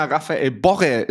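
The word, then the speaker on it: Rafael Borré: Äh, wie schön Lena Raphael Borre, es ist auch richtig so ausgesprochen, ausgespro äh, also äh, betont hat.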